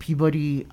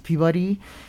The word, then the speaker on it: Peabody: and she started this with the Harvard Peabody Museum. So I learned when we went to Harvard, we just got back from Harvard in June, that it's pronounced Peabody, not Peabody, they hold like about a million pieces of our ancestral belongings, Laura.